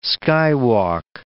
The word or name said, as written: skywalk